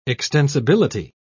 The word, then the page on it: extensibility: British English